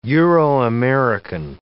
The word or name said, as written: Euro-American